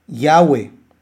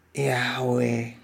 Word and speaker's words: Yahweh: Esas son las mismas vocales comúnmente pronunciadas, Yahweh, reconociendo la Yud y la Vav, que no son pronunciadas tan fuerte como consonantes.